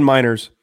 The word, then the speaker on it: Meinerz: I mean, was it um buddy on the Broncos, uh, Quinn Merritt's or Mer- Qu- Quinn Meinerz. Yeah, yeah, yeah. He, uh, way to go, with that pronunciation. That was not even close.